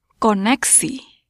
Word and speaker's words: koneksi: Listen and repeat. – Koneksi. Ko-nek-si.